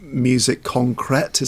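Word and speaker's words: musique concrète: music concrete. Is that how you say it? Yeah, or music yeah.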